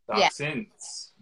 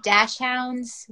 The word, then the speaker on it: dachshund: Dachshunds. – Multiples, okay. – Dachshunds. Some people say dash hounds. We say dachshunds.